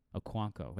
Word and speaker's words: Okonkwo: O-K-O-N-K-W-O. That's how he spelled his last name. But he absolutely dominated. 6-6 for 45-1. It's called touchdown chasing, guys. Don't. – Okwankwo? – Okwankwo.